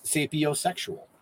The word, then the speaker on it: Sapiosexual: Called Sapiosexual. – Yep. – Did I pronounce that right? – Yeah, completely.